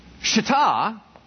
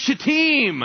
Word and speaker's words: Shittim: Then I had all my Hebrew folks come up and go, it's pronounced Shittim.